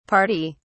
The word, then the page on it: pardy: British English